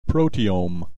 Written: proteome

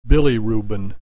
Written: bilirubin

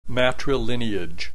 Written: matrilineage

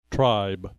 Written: tribe

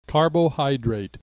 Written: carbohydrate

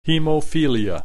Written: hemophilia

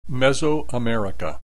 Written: Mesoamerica

